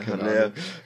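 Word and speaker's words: Haller: spricht man aus? Alleur. der ist ja Franzose. – Ja, Aller.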